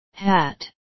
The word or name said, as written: hat